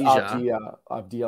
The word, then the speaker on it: Avdija: I think it's Avdija, like it's a Y.